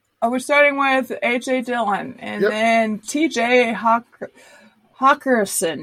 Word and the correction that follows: Hockenson: Am I saying that right? – Hockenson.